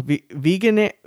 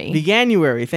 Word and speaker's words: Veganuary: This year's, I'm going to say this wrong, Veganuary. – Veganuary, thank you.